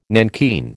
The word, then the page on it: nankeen: American English